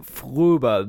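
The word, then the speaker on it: Fröbe: I think you'll find it is pronounced Gert Fruba. – One more time. So. – Fruba, because there's a fucking umlaut, and that's how it goes.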